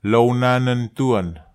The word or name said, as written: lownanentuan